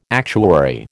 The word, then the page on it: actuary: American English